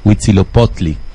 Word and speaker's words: Huitzilopochtli: El Jehová de los aztecas, Huitzilopochtli.